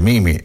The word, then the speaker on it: meme: Dice, dite Mimi o dite meme? Non so mai come pronunciarlo. In inglese si dice Mimi, ma l'italiano che facciamo? Manteniamo la pronuncia inglese o lo chiamiamo meme? Questo è un dilemma delle 7 del mattino.